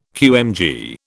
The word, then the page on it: QMG: British English